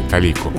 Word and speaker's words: талику: Но если уж где-то Э, вы э, позволяете себе талику некоторого вымысла, пусть это будет хотя бы э, на уровне лжи, а не вранья. Так что ли? – Я обратил внимание на прекрасное ударение талику. Обратили?